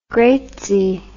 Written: greatsie

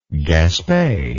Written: Gaspe

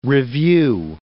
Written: review

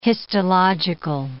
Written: histological

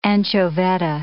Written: anchovetta

